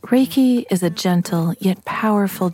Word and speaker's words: Reiki: Translated as universal life force energy, Reiki, pronounced Reiki,